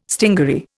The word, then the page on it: stingaree: Indian